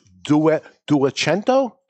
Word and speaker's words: Duecento: Duecento. – You're Italian. I'll go with your pronunciation. – Here we go. – Duecento.